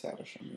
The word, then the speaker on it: Havisham: Havisham, but am I saying that correctly? – I think it's Havisham, yeah.